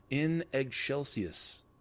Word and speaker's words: in excelsis: Because they did not, because the actual pronunciation for that is in excelsis. So it's almost like you're saying eggshells, like eggshell, not excels, but eggshells.